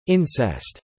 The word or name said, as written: incest